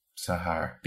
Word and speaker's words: Zohar: It was like spelled Zohar. – Yeah. – But like if you say it fast, it's like Zahar.